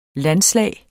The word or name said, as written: landslag